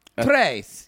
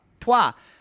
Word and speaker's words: trois: Tres. Yeah. – Trois.